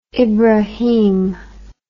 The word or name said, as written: ibrahim